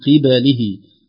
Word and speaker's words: قبله: من قبله العذاب، كلمة قبله تشبه كلمة قبله في النطق، فربما البعض يشتبه عليه فننطقها بكسرة القاف وفتح الباء ثم كسرة اللام والهاء. من قبله، وتربصتم، وليست وتربى، بصتم، الراء مفخمة قبل الباء والصاد مفخمة بعدها فربما ضاعت الباء بينهما، لكن ينبغي علينا أن نحذر.